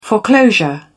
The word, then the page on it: foreclosure: British English